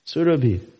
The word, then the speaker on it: Surabhi: The spiritual boat is known as Goloka, which is a pasturing land for Surabhi cows. Just FYI, it is Surabhi. Surabhi. No. That's like saying Radhika. It's Radhika. Surabhi. There's no log A over the... There's no light over the A. Surabhi. Surabhi abhipalayantam.